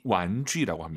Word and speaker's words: 玩具: 장난감을 중국어로 완주. – 완쥐라고 합니다. 제이 유발음 잘하세요? 주 아니고 쥐입니다, 여러분. 완쥐.